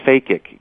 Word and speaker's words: phakic: Phakic. It comes from a Faked. Greek. It comes from the Greek word phakos, P-H-A-K-O-S, which means lens.